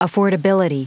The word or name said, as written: affordability